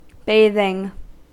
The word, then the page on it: bathing: American English